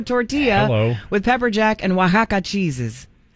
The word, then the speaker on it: Oaxaca: When in all actuality is pronounced Oaxaca.